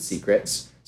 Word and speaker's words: SCRT: So secret, which is SCRT, yeah, but we pronounce it secrets. So I stake my secrets, I send you secrets.